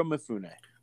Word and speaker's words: Mifune: Is that how you say his name? – Yep, Toshiro Mifune.